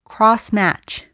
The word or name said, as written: crossmatch